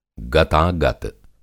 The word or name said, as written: गतागत